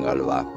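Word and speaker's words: Gauloises: Se pronuncian Galois, se escriben Golous, pero se pronuncian Galois.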